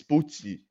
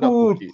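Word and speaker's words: Pukki: Oh, Pookie is the Norwich player. This is Pucci, not Pookie.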